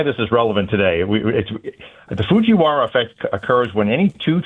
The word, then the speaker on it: Fujiwara: It's the Fujirawa. – Fujiwara. I got that right? – Fujiwara effect.